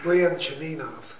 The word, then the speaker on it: Brianchaninov: Bishop Ignatius, it's been a long time since I've read him, so I've forgotten how to pronounce his name. – Brianchaninov. – Thank you, that's perfect.